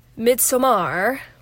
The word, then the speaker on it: Midsommar: When I had a film history class, they assigned Midsommar, that's the correct way to say it, and Hereditary as homework viewing assignments, and I care about getting a good grade.